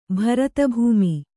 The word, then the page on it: ಭರತಭೂಮಿ: kannada